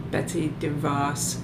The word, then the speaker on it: Betsy DeVos: Phyllis Schlafly, Betsy DeVos. Did I say her name right? – I think you did, yeah.